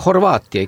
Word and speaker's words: Horvaatia: Seda niimoodi ikka nagu on korrektne hääldada, et nende puhul on ju ka nii, et nad aegalt mõtlevad seal, et kuidas see nagu õige kõla on või ka Horvaatia ikka. – Horvaatia, jah.